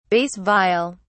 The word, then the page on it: bass viol: British English